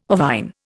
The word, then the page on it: ovine: Indian